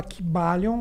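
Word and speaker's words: Kybalion: Se fala Kibalion, mas é Caibalion que se escreve, né?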